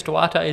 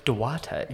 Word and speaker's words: Duarte: Chris Duarte is a really good first-year rookie. – I think that's the first time you said his name correctly. – Duarte.